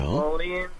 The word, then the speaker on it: Thessalonians: Okay, so the first one is about second, I don't know how to say the name, T-H-E-S-S. – Thessalonians? – Mm-hmm. To the Bible, sort of. – Okay, yeah, Second Thessalonians, yeah. – Thessalonians.